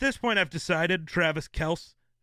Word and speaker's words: Kelce: Yeah, he came out and said that's how you're supposed to say it. It's Kelce, not Kelce. – He's wrong. – No, it's Kelce, bro.